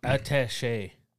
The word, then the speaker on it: attaché: It's attaché.